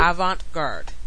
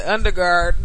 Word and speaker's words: avant-garde: Avant-garde. – That shit. Now. – His nigga said underguard.